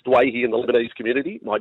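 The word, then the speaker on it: Doueihi: Well, this week, going into game one of the World Cup, he has set the record straight and said, I'd like to be known as Dwayhi, which is the correct Lebanese pronunciation. So, we respect Adam's wishes. It is Adam Dwayhi from this day forth, Sats.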